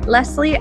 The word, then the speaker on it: Lesley: If we aren't yet connected on Instagram, come follow me at Lesley and Co XO.